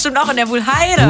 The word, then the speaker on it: Wuhlheide: Ich find's übrigens lustig, wie du Wuhlheide ausgesprochen hast. – Wuhlheide, was sag ich denn? – Wuhlheide? Wuhlheide. – Die Wuhlheide. Ich sag mal Heide eher.